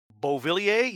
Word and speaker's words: Beauvillier: Is that really how you say his name? – Beauvillier, yes.